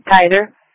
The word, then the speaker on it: Keizer: How do you pronounce that? K-E-I-Z-E-R. – Yeah, you're right. Kaiser.